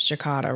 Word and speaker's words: Jacada: So, Michael, let me ask you, if people want to know more about Jacada, and I'm saying it wrong, it's Jacada, right? – Right.